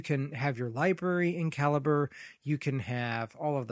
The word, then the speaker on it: Calibre: Now, some people pronounce it Calibre, which I guess is okay, but I've heard the guy that does the Calibre project call it Calibre. So that's C-A-L-I-B-R-E, if you wonder why I'm making a big deal of the pronunciation.